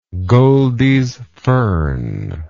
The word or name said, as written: goldies fern